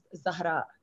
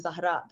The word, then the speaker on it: Zahraa: So people say ZAHRAA and I'm like, yeah, that's fine. But my real name is actually ZAHRAA. That's the correct pronunciation.